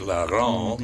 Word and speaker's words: Laurent: She pronounces it Laurent, mm-hmm. the French way.